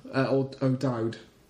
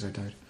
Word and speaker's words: O'Dowd: O'Dwan. I think that's how you pronounce it. O'Dowd. Is O'Dowd.